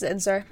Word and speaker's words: Zinsser: And I also recently bought Bird by Bird, which is by Anne Lamott, and On Writing Well by William Zinsser, I believe is how you pronounce that. I could be wrong. I always spell it incorrectly too, because I think it's, you know, the S's and the N are flipped. So yeah, it's Zinsser.